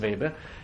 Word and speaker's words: Weber: Non c'è un sapere oggettivo, come credo di aver ormai detto e ripetuto estensivamente anche con l'aiuto del nostro simpatico Max Weber. Mi raccomando, Weber, la pronuncia è V, voi dite Volkswagen, non dite Volkswagen, non è inglese.